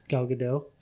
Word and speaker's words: Gal Gadot: Gal Gadot. How do you say her name? – Gal Gadot.